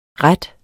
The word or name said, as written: ret